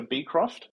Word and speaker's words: Beecroft: We tend to emphasize the B, so Beecroft.